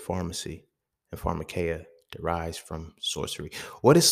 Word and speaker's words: pharmakeia: So when we look at the root word of pharmacy, pharmaceuticals, all of those, it's pharmakeia, right? Pharmakeia, it is spelled P-H-A-R-M-A-K-E-I-A, I believe, but it's pharmakeia, right?